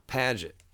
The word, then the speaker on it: Padgett: Padgett. – Spell that? – P-A-D-G-E-T-T.